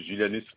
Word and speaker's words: Julianus: c'est Julianus Philippe. – Alors, Julanis. – Comme ça prononce Julianus Philippe.